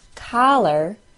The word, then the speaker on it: collar: The words caller, C-A-L-L-E-R, and collar, C-O-L-L-A-R. C-A-L-L-E-R is said by most with an A-W sound. Caller. C-O-L-L-A-R is said by most with a short O sound. Collar. I'll say them again in the same order. C-A-L-L-E-R, A-W sound. C-O-L-L-A-R, short O sound.